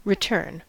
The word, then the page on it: return: /ɹɪ.ˈtɜːn/; [ɹɪ.ˈtɜːn]; /ɹɪˈtɝn/